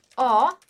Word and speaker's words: ho: Então, ho il tuo ombrello. O que que a gente tem aqui? De novo, o verbo avere, né? Que a gente sempre vai escrever com H, mas não vai pronunciar.